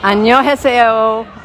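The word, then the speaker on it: Annyeonghaseyo (안녕하세요): Out of sheer nervousness, I said, "Annyeonghaseyo, annyeonghaseyo." And he pointed his finger at me and said, "You, your pronunciation is very good."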